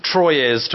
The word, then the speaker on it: Troyes: We played Troyes, T-R-O-Y-E-S. I don't know if I pronounce it right. I'm not very good with that. – Yeah. Troyes to us gamers.